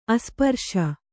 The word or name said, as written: ಅಸ್ಪರ್ಶ